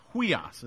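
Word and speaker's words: Huios (υἱός): That's in the right-hand column, H-U-I-H-O-S in the chart. Huios is how that's pronounced.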